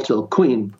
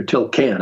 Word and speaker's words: Tilquin: So that night, a Belgian customs officer, Paul Tilquin, or Tilquin, I should say, pronounce it in French, came on a tandem bicycle to take him to another location.